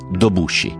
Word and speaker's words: Dobusci: Da piccolo le sue scarpe avevano sempre dei buchi nella suola, così gli amici, come soprannome, scelsero Due Buchi, che in dialetto brindisino si pronuncia Dobusci.